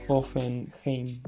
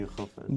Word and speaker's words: Hoffenheim: Hoffenheim. – Ah, sí, Hoffenheim.